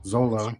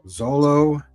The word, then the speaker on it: Xolo: X-O-L-O is his first name. – Zolo. – And his last name is M-A-R-I-D-U-E-N with that squiggly thing above the N-A. – Zolo Maradina.